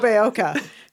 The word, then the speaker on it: Nobeoka: And everyone back in his hometown of Noboeko will be Noboeki. Noboeko. – Nobeoka. – His hometown of Nobeoka.